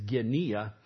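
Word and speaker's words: genia (γενεά): There is no soft G in the Greek language. It is genia, and it is from genia, which means kinfolk, people you're related to, the community.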